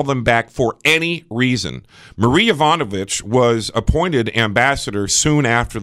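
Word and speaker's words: Marie Yovanovitch: Marie Ivanovich. – Yeah. – Thank you, because I didn't want to butcher the name. – You perfectly pronounced it.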